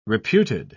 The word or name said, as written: reputed